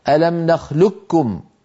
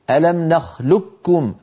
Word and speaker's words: نخلقكم: أما نخلقكم فالقاف نعم مستعلية، لكن العرب استساغوا أن ينطقوها كاف مشددة فيقولون ألم نخلقكم ألم نخلقكم، فيدغمون القاف في الكاف فيكون النطق بكاف مشددة.